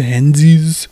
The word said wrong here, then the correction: Heinz: ketchup. – Heinz.